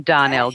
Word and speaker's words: Darnell: So Cindy as in Cindy Lauper, not as in Cindy Crawford, C-Y-N-D-Y. And Darnell, D-A-R-N-E-L-L.